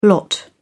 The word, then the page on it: lot: British English